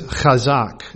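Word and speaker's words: chazak (חזק): In the Hebrew, the principal word used translated encourage in the authorized version of the Old Testament is chazak. It's spelled C-H-A-Z-A-Q, chazak. And that does mean to strengthen.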